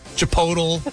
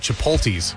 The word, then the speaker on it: Chipotle: Chipotle. – Yeah. – Chipotle. Yeah. – That's I would always put the T in front of the P. – My dad pluralizes it. He calls it Chipotles or something like that.